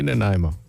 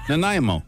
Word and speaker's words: Nanaimo: Say Nanaimo, huh? Nanaimo.